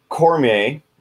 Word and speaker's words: Cormier: This one is the Fight Pit match with Daniel Cormier.